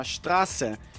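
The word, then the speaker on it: Straße: Então você pega a palavra rua, por exemplo, que chama Strasse, usualmente é escrito com esse beta. Mas é como se fosse dois S a pronúncia.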